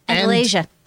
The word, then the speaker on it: Andalasia: Andalasia.